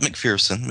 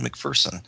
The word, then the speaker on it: McPherson: Not McPherson, McPherson.